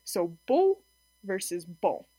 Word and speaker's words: beau: So this would be a non-nasalized vowel in the word beau, beau, and then nasalized would be bon, bon. So in a nasalized vowel, nasal vowel, some of the air actually comes through your nose when you pronounce the vowel, not just through your mouth.